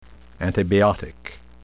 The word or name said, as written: antibiotic